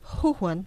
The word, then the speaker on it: Wuhan: Wuhan, obrigada.